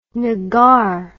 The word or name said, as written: nuggar